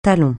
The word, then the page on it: talon: francais